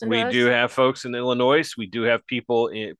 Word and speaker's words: Illinois: But a couple of months ago, we talked about the super mayor, Tiffany Henyard from Dalton, Illinois, because you got to pronounce the S at the end of Illinois.